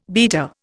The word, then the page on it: beta: Indian